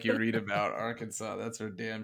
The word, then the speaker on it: Arkansas: The S is silent, by the way. – I said Arkansas.